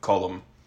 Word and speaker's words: Colm: Colm and Patrick and Colm. They pronounce, like, a space between the L and the M there.